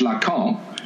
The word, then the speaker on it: Lacan: Oh no, because no, no, because when you say in French Lacan, you can spell it LACAMP. So had his name been LACAMP. You would have pronounced it in exactly the same way, right?